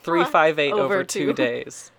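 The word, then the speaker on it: 358/2 Days: And it is pronounced that way. 358 over two days.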